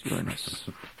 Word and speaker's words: Uranus: Yes, Uranus.